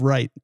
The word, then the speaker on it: Wright: And then the last name of Wright. Yeah. Okay. Okay. – Yep. Exactly. I mean, it's W-R-I, but you know, the W is silent.